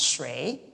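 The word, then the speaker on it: shui (水): And then the the next character here is water, pronounced shui.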